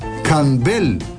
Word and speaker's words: Campbell: Campbell, acrescentou enfatizando a última sílaba.